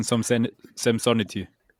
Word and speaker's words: Samsonite: Die Samsonite-Rucksäcke heißen Samsonity.